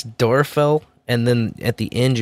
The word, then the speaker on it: Doerfel: People don't even say, hey, we're the Dorfels, and people are like, the what? A door, the door. And then if you look at how it's spelled, it's like Doerfel. So Dorfel, Dorfel. Like, what?